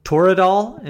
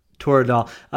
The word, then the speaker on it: Toradol: And I think the first one, and the one that Peter King, you know, gassed you up for, was the Toradol. Am I pronouncing that right? – Yes. – Toradol.